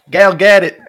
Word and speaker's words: Gal Gadot: What is it, Gal Gadot? – Gal get it.